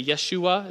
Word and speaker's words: Yeshua: In the Hebrew, Jesus, in the Hebrew you would say Yeshua, is how you pronounce it in Hebrew.